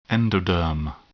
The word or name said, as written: endoderm